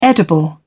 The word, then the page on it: edible: British English